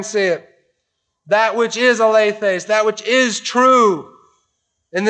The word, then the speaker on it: alethes: And it's, the, it's the, an adverbial form, an adverb form of the word alethes, a L E T H, long E S.